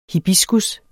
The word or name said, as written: hibiscus